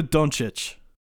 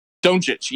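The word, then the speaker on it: Dončić: Rookie of the year, I'm guessing, is going to be Luka. Was it Don? I can't pronounce names, man. – Luka Doncic. – Doncic, yeah, that's right.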